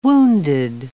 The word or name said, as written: wounded